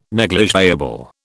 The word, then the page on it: negligible: British English